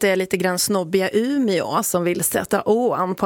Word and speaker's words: Umeå: Tycker du jag var hård förut, Evelina, när jag sa att det heter Umeå, inte Ume? – Nej, men jag tycker det är bra. Det är ju vissa som har den åsikten att vi i radio ska uttala åen också.